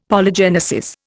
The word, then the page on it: Polygenesis: Indian